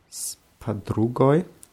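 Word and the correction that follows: Spadrugai (с подругой): Bei spadrugai haben wir die Betonung auf dem u, deswegen wird oi so ein bisschen wie ei ausgesprochen.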